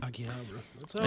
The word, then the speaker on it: Akihabara: How do you say it? Akihabara? Really? – Akihabara.